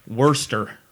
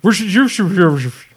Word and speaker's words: Worcestershire: Wait, how do you say that? – Worcester. – Worcestershire. – I say Worcestershire. That's my version of it. – Yeah, Worcestershire. – Sure. I get a fourth syllable in there personally.